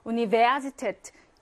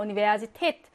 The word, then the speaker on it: Universität: Universität, 강세는 뒤에 있어. Universität. 근데 위치가, 이 파우가 중간에 있죠? 그래서 V로 발음합니다.